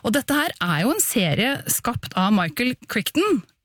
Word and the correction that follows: Crichton: Eller Crichton, tror jeg. – Ja, takk, jeg kan ikke uttale noen ting i dag.